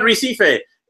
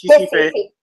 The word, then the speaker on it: Recife: So it's not Recife. The R is silent. So it's Recife.